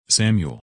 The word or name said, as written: Samuel